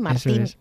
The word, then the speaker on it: Martín: El protagonista, sí, de hecho, se llama, igual que tú, pero con acento en la I, Martín.